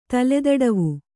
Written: ತಲೆದಡವು

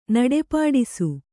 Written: ನಡಪಾಡಿಸು

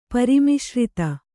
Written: ಪರಿಮಿಶ್ರಿತ

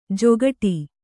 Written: ಜೊಗಟಿ

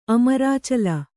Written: ಅಮರಾಚಲ

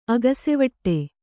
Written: ಅಗಸೆವಟ್ಟೆ

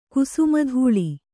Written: ಕ್ಷಮಾತಳ

